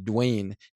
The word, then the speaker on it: Duane: So for anyone listening to this, honestly, send me a direct email, like email me, Dwayne, D-U-A-N-E, like Dwayne Reed.